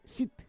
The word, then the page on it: sit: Roon